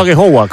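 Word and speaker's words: Agehowa: he dicho Agejogua, ¿cómo se pronuncia? – Sí, bien, la verdad que...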